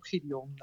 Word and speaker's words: Gideon: Jij bent onder meer actief via eh, de, binnen de Gideon Stripe, of moet ik het op zijn Engels uitspreken? Gideon Stripe. nou ja, ik, eh, internationale ambities zullen er zeker zijn, maar eh, we houden het zelf nog even gewoon op Gideon.